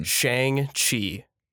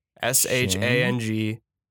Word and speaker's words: Shang-Chi: Shang-Chi. – S-H-A-N-G-Chi. – And then Chi, C-H-I.